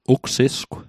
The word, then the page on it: ugsisgw: Mi'gmaq